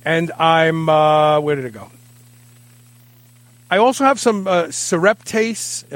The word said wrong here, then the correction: serrapeptase: Serrapeptase. – Serrapeptase.